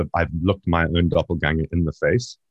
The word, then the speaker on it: doppelganger: Um, but the, uh, like the from a paranormal perspective, a doppelganger, and if we're going to do the correct pronunciation, it's German, it's doppelganger.